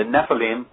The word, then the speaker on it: Nephilim: And by the way, the name of my book is The Nephilim, and that's spelled N-E-P-H-I-L-I-M. The Nephilim, or Nephilim, and the Pyramid of the Apocalypse.